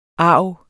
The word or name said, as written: argeste